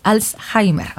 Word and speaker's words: Alzheimer: Alzheimer, ok, Alzheimer, porque hoy se conmemora el Día Mundial, lo que te platicaba al inicio del programa.